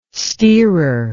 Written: steerer

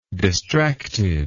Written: distracted

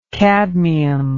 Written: cadmic

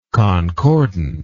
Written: concordantly

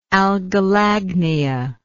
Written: algolagnist

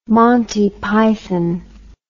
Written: montypython

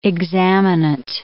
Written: examinant

